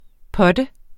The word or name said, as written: putter